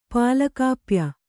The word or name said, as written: ಪಾಲಕಾಪ್ಯ